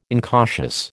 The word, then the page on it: incautious: American English